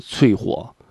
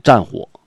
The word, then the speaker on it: 淬火: 你比如说有一种那三个字儿，那个字儿呢，三点水搁一个足，冰足啊的足，那个字儿念脆啊，但是呢这个字呢就是这一个发音脆火，但是你在那个什么呢，在专业领域你写出这俩字儿的老师傅就念战火。